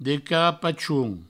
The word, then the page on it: de carapachons: Provençal; de carapachoun